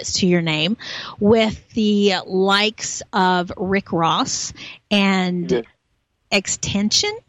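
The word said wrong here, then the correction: XXXTentacion: Is that how you say it? – XXX Tentacion. Yeah.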